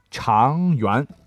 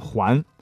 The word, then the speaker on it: 长垣: 河南省的最后一个被誉为厨师之乡的长垣，不不，长垣啊，你不能读成环，就是残垣断壁、垣墙的那个垣字啊。